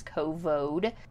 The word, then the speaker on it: Covode: And I'm trying to be very careful how I pronounce that because it's not the other C word, it's Covode.